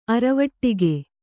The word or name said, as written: ಅರವಟಿಗೆ